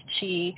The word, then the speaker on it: qi: And then we're also working with this concept of chi, A lot of people see it as QI. It's the old Taoist way of how to spell it. That's how I spell it. It's pronounced chi. Some people see it the Japanese way of KI. It's pronounced ki.